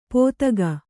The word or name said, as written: ಪೋತಕ